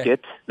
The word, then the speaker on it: Prescott: Prescott, they say out there. – yep. – Pronounced Prescott. – Yeah. – So I was in Prescott Valley, and I worked at Bradshaw Mountain High School for 3 years out there.